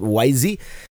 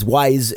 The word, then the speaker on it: Wyze: The camera is, I was tempted to pronounce it YZ, but I guess it's Wyze is the name, the Wyze version 3.